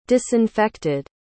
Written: disinfected

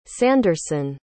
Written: sanderson

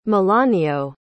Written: melanio

